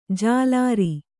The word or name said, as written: ಜಲರಿ